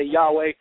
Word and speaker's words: Jehovah: which I believe is pronounced Jehovah. Some say Jehovah, some say Yahuwah, some say Yahweh.